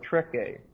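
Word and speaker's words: trechē: This word, keep on advancing, which the King James translates that it may have free course, is the word treke. Treke. This is a subjunctive form of treko. T-R-E-C-H, long E.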